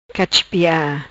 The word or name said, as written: Cachipia